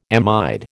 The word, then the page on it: amide: American English